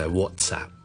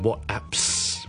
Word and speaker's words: WhatsApp: 那第三个就是 WhatsApp，不是 WhatApps， but WhatsApp 扔埋的 WhatTS 扔埋个 App 就变成 WhatsApp。所以讲到最后一次 Uber LinkedIn and WhatsApp。